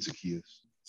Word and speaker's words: Zaccheaus: Zahacius. How do you say his name? – Uh, Olamide Zaccheaus. Zaccheaus.